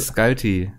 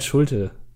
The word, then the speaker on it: Schulte: Also, Michael äh, Scalti. – Michael Schulte. Ah, wow. Man muss ihn international aussprechen, dann ist das, kommt das ah, gleich ein ah. bisschen größer. Ja.